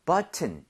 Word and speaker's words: button: 앞쪽에 강세를 엄청 주셔야 돼요. 그래서 여기 강세를 많이 줘서 button 이것도 돼요. 되는데 원어민들이 앞쪽에 강세를 많이 주면서 t를 그냥 거의 없어지게 만들 때가 있어요.